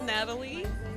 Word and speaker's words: Nataly: Alright, my name's Natalie. N-A-T-A-L-Y, Nava, N-A-V-A.